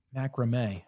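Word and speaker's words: macramé: Macrame.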